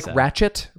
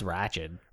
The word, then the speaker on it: Ratched: Do we pronounce it Ratched? Like, yeah. – Ratchet. Ratched. – I think it's Ratched.